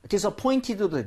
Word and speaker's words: disappointed: 얘 같은 경우에는 NT가 붙어 있죠. – 네. – NT가 모음 사이에서 이렇게 붙어 있으면요. T가 약화될 수 있는 옵션이 있습니다. 꼭 그렇게 안 해도 되는데 disappointed도 되지만 disappointed 이렇게 해서 T를 날리기도 해요.